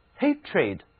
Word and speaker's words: hatred: 字母 e 虽然常常读作 a 或者是 a，但是在这里呢却读作短 e， hatred，憎恨。